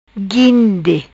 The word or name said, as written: Guinde